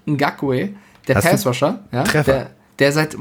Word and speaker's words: Ngakoue: Yannick Ngakoue.